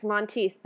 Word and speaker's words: Monteith: Yes, Monteith, thank you.